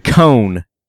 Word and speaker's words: Cohn: Yeah, it's Aaron Cohn. Cohn. – There you yeah, go. And they may pronounce it differently, but it's C O H N or something like that.